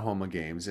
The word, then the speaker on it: Homa Games: Well, what's interesting about Homa Games, and again, you guys know French better than me. Apparently, I should be pronouncing it Homa games, all my games.